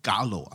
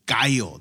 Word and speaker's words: Gallo: I'm not calling it Gallo. I'm calling it Gallo. That's Spanish for rooster.